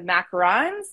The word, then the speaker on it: macaron: Yeah, it's tricky because it's, you macarons. Know, it's one vowel difference. But the macaroon is the one that's, you know, surrounded by coconut. Whereas the macaron is, you know, the little sandwich cookie.